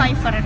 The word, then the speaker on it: Pfeiffer: Pfeiffer.